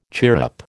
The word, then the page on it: chirrup: American English